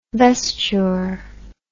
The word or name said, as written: vesturer